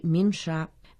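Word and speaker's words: Минша (鸣沙): Давай разберем название горы Минша. Минша в переводе означает «горы воющих песков». Мин означает «петь», произносится во втором тоне, а ша – это «песок», произносится в первом тоне.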